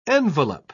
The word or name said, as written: envelop